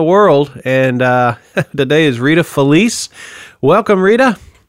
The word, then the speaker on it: Felice: And in Italian, it's pronounced Felice. – Felice.